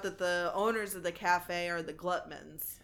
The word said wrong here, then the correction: Guttmans: I think that's how it's pronounced. – I think it's Guttmans.